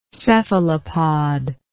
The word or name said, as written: cephalopodan